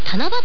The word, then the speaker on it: 七夕: あ、なるほどね、だから正確に言うと年に 3 回あると言ってもいいんですね、でこの七夕、 3 つの行事が合わさってできたと言われています、先ほどの伝説の他に七夕、字で書くと棚、木偏に月付きの棚ですね、に機械の木と書いて七夕と読むんですが、